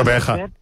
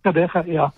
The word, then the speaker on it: Gqeberha: You know, Gqeberha. Gqeberha.